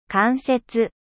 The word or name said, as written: indirect